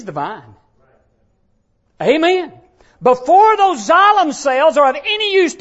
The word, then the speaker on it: xylem: X-Y-L-E-M. Xylem is how it's pronounced. It's part of what happens with photosynthesis when you study that subject. And xylem in a tree, it's a tissue, and it's called X-Y-L-E-M. It's pronounced xylem.